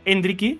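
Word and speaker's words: Endrick: Y todo ha cambiado en la segunda mitad cuando ha entrado Endriki. Vamos a ver si lo pronunciamos como los brasileños.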